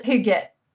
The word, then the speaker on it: hygge: And hygge, am I pronouncing that right? – Well, that's Danish, so that's a little out of my expertise since I'm Swedish, okay. But I would pronounce it hygge.